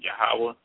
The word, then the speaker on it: Yahweh: And just like he mentioned that when we talk about the Yod-Heh-Wah-Heh, we pronounce the Most High's name Yahweh.